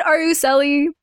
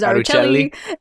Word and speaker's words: Aruceli: I say it Aruceli, but because of how it's spelled, a lot of people say it with the Italian accent, which is Aruceli, except it's not how you say it, but I also recognize that was my mistake, so I never stop anyone from saying it.